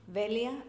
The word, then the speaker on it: Velia: Velia, V-E-L-I-A, Sanchez, S-A-N-C-H-E-Z, hyphen, R-U-I-Z.